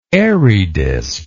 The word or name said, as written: Airy disc